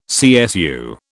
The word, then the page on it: CSU: British English